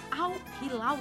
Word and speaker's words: Al Hilal: Mas além do Bangu, teve Olaria, Vasco, é, Botafogo, o Al Hilal da Arábia. Como é que fala isso? É isso é, mesmo? É, Al Hilal. Acertei. – Acertou.